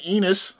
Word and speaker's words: Enos: Another one, Enos, I think, is how you say E-N-O-S. – Okay, sounds right.